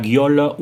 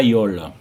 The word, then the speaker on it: giola: Beleu cau d'obrir ici une parenthèse per trencar la question, se deben dire la giola ou la iola. Les deux prononciations sont correctes. La iola est simplement la prononciation dialectale de l'occitan nord à Beirunès.